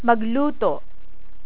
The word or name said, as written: magluto